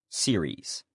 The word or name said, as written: series